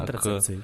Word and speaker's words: контрацепции: И, как мне кажется, именно из-за этого у нас э, э дети пренебрегают к концентративам, правильно сказал, не знаю, неправильно. – Контрацепции. К, да, к контрацепция вот, э, делают там необдуманные шаги, идут там на тусовки, где их там насилуют и так далее.